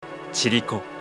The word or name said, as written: Chiriko